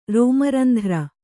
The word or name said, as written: ರೋಮರಂಧ್ರ